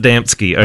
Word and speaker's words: Adamski: It's pronounced Adamski.